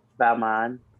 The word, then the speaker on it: Balmain: Balmain.